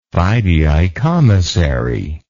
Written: fideicommissary

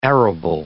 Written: arable

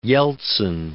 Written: Yeltsin

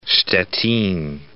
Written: Stettin

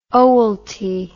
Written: owelty

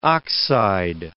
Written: oxide

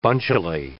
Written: bunchily

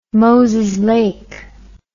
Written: Moses Lake

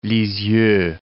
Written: Lisieux

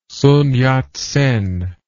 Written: Sun Yat-sen

